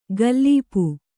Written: ಗಲ್ಲೀಪು

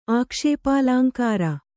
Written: ಆಕ್ಷೇಪಾಲಾಂಕಾರ

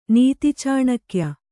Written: ನೀತಿಚಾಣಕ್ಯ

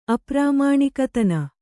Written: ಅಪ್ರಾಮಾಣಿಕತನ

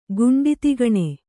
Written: ಗುಂಡಿತಿಗಣೆ

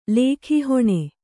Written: ಲೇಖೀಹೊಣೆ

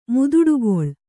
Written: ಮುದುಡುಗೊಳ್

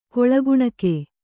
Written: ಕೊಳಗುಣಕೆ

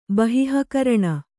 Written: ಬಹಿಃಕರಣ

